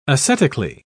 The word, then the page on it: ascetically: British English